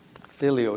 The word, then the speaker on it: filial: Filial is probably not a word that some of us are familiar with. It's spelled as F-I-L-I-A-L, filial, which means something of a son or a daughter.